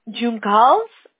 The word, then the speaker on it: Juncal: Do you pronounce yes. – the J? – No, you say Juncal.